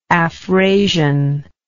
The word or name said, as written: afrasian